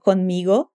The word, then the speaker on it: conmigo: Puedes ir conmigo en mi carro. O Luciano pode ir com ela no carro. Atenção à pronúncia da palavra comigo em espanhol. Conmigo. Puedes ir conmigo.